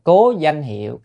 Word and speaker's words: cố danh hiệu: Cố danh hiệu, phải đọc là cố danh hiệu công đức bất khả tư nghì.